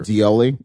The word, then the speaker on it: Dioly: Dioli, it's exactly how it's spelled. – It's a beautiful name. – D-I-O-L-Y, yeah.